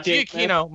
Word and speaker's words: Giacchino: Oh no, Giacchino.